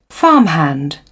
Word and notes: farmhand: British English

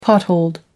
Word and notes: potholed: American English

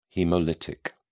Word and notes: haemolytic: British English

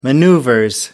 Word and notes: maneuvers: British English

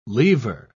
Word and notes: leaver: British English

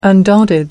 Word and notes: undaunted: American English

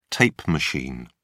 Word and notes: tape machine: British English

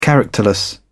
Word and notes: characterless: British English

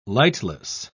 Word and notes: lightless: British English

